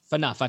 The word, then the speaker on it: Phaneuf: And Dion Phaneuf. I'm going to look to you. Am I pronouncing that right? – Phaneuf.